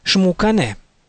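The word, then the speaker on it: Xmucane: Говоря о сотворении мира, книга Пополь Вух называет прародителей мира именами Шпиякок и Шмукане. Отметим для дальнейшего, что начальная буква «Х» в определенных словах киче и в начале собственных имен произносится как «ше».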